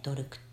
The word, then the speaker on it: 土鬼: ドルクっていうあの土に鬼でドルクって読むんですけどこっちはあの連合連合体なんですよいろんな諸国が集まってドルク